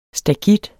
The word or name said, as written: STAKITTERNES